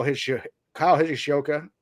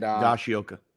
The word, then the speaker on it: Higashioka: Kyle Higashioka and Higashioka.